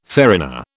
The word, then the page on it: farina: British English